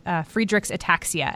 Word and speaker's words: Friedreich's ataxia: And that is your recent diagnosis with Friedreich's ataxia.